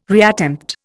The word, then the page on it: reattempt: Indian